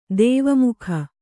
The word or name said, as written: ದ್ವಿಮುಖ